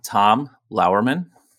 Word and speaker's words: Tom Lauerman: I'm Tom Lauerman.